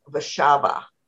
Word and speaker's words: Warszawa: For instance, Warsaw in Polish is Warszawa.